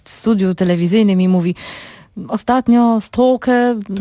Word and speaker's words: stalker: Stalker. – Mówi się po angielsku stalker.